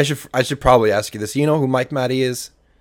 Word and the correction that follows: Matei: Oh, Mike Matei. Oh, okay. Mike Matei. Yeah, oh, yeah, it's, Mike Matei. I said the name wrong.